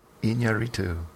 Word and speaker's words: Iñárritu: It's I-N with a squiggle over it, A with an accent over it, two R's, I-T-U. – Of course. – Iñárritu. Alejandro Iñárritu.